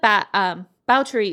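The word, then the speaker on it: Báthory: Yeah, so Elizabeth Bauteri is the correct pronunciation of her name. It's spelled like Bathory, and it's how I pronounce it in my head, so I'm sorry in advance if I slip up.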